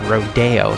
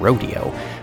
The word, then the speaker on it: Rodeo: Also, I pronounced Aaron Copland's uh, ballet work as rodeo instead of rodeo, uh, which I blame on uh, living in Los Angeles uh, and uh, hearing about Rodeo Drive all the time.